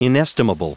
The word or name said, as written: inestimable